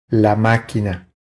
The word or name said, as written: La maquina